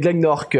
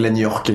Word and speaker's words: Glenorc: Glagnork ou Glagnork ou C'est Glenorc, alors les personnes arrivent plus ou moins bien à le prononcer, notamment quelques Belges, il y a pas mal de Belges qui ont un peu de mal à le prononcer, comme une des actrices qui joue dans la saga qui arrive pas à prononcer le nom, mais c'est plutôt rigolo.